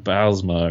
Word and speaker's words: Bylsma: Because if, like, Crosby would probably love to have Mike Babcock, where I'm not sure who's out there going, like, yeah, Dan Bylsma, or how do you say the guy's name? – Yeah, is that his? Like, you nailed it.